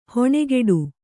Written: ಹಣೆಕಟ್ಟು

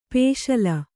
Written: ಪಸಲು

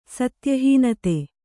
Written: ಸತ್ಯಹೀನತೆ

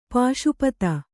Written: ಪಾಶುಪತ

